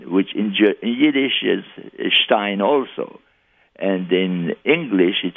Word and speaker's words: Stein: The German word Stein, it's pronounced with a sh.